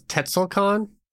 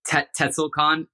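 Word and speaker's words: Tzekel-Kan: The last part of it's Khan, T-Z-E-K-E-L. – I think it was Tetzelcon. – Tetzelcon.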